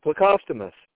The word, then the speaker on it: Plecostomus: Plecostomus. That's how you pronounce it, Plecostomus. Wow, I murdered it.